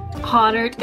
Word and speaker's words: Hahnert: Is it Hahnert? Or tell me how to say it right. – Hahnert is correct.